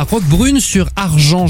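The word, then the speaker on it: Roquebrune-sur-Argens: Vous êtes à Roquebrune sur Argent, j'ai bien prononcé ? – C'est parfait.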